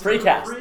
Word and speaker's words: Fracast: We're known as the, how do we pronounce it? The Fracast? – The Fracast? – The Fracast. – Fracast. – The Fracast. – Fracast.